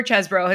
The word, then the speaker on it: Chesebro: So according to the Washington Post and Airmail, it is pronounced Chesebro, but his nickname in college or law school, I'm not sure which, was the Cheese. So you would be justified in thinking it was Chesebro.